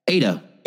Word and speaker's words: Ada: Ada would be A-D-A. Yeah, A-D-A, right? – Yeah. – Ada. – Ada? Oh, weird. – Ada. Like Ada Wong from Resident Evil.